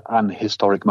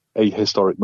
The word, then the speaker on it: historic: Cannot stand when people say it is an historic moment because they're not pronouncing the H, they're going straight to the I. So I say it is a hip. It is a historic moment.